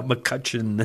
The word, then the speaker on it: McCutchen: For the record, for everyone, my last name is not spelled with an O. – Okay. – It's a U and an E, McCutcheon.